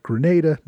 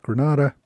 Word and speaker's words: Grenada: I should be calling it Grenada, not Granada, but as far as I can tell, either pronunciation is acceptable.